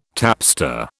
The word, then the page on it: tapster: British English